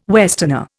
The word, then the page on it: westerner: Indian